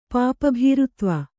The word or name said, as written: ಪಾಪಭೀರುತ್ವ